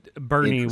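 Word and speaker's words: Boerne: Bernie, which is spelled like Buerne, which I fucking hate.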